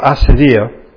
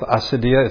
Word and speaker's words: acedia: "The demon of Acedia," let me just say, I've seen it pronounced both Acedia, it's A-C-E-D-I-A, or Acedia, means sloth.